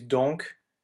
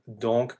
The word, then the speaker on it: donc: Yeah, it can happen, especially when you use donc as a conjunction, like therefore, then the C will be pronounced as a K. So in Carmen, we have donc pour me tenir compagnie, the K is pronounced. But in here, the UNC, it serves a different grammatical purpose and it's not pronounced.